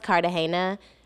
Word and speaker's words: Cartagena: And it was I think it's pronounced Cartagena, but I don't mm-hmm.